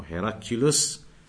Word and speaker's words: Heraclius: In Arabic known as Heracl, and in English pronounced as Heracles or Heraclius, that Caesar of Rome.